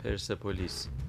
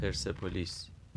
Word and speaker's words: پرسپولیس: پرسپولیس پرسپولیس پرسپولیس